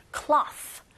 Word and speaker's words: cloth: 그래서 C자 거꾸로의 발음 기호를 보시면 입을 오 한 상태에서 위아래로 벌리는 느낌으로 이 동작을 해주시면 돼요. 그래서 입을 벌리셔서 cloth, cloth.